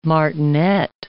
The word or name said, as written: martinet